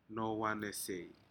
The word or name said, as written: nohowanesei